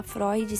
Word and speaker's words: Freud: Freud, que se fala Freud, se escreve Freud.